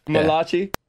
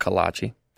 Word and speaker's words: Malachi: Malachi? – Malachi. Not Kalachi.